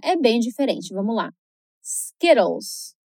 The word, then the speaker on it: Skittles: Bom, aqui a gente tem três dicas para prestar atenção. A primeira é o som da vogal I. – Quando o I aparece antes de duas consoantes repetidas, ele vai ter um som curto. Então vai soar um pouquinho como o nosso I do português. Por exemplo, different se escreve com dois Fs. Para que o I tenha esse som curtinho e não de I, como a gente geralmente escuta. Então, de novo, skit, Skittles.